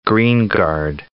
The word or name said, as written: Greengard